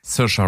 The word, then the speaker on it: Saoirse: Cersei Ronan? Ich kann sie mal nicht aussprechen. Saoirse. – Hm? Saoirse? – Saoirse Ronan, ja.